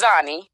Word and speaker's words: Xani: So it's actually pronounced Xani. – Okay. – Yep. So I go by Xani.